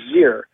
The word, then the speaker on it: xyr: Well, it's spelled X-E, X-E-M, and X-Y-R, and it's pronounced Z, Zem, and Zir.